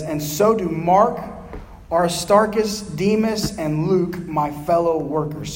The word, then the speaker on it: Demas: He selected this man named Demas, D-E-M-A-S, or Demas, however you might want to say that. I only heard it pronounced Demas, to be his close companion in the ministry.